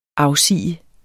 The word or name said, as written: afsige